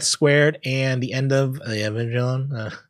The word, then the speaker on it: Evangelion: And it is official, according to Netflix, the show, and I'm going to always say it wrong, probably Neon Genesis Evangelion? How do you say that? – Evangelion. – Oh, yeah, okay. Yeah, I was so close.